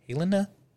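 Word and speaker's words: Halyna: Halen Hutchins. I think that's how you say her name. Helena. It's Helena.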